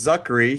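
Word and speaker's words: Czuchry: I think it's pronounced Zuckery.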